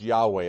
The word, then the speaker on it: Yahweh: If you'll notice that the word Lord there is in all caps, that would be the word that is usually referred to as Jehovah, sometimes pronounced Yahweh.